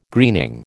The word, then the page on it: greening: American English